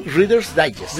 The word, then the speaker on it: Reader's Digest: Reader's Digest.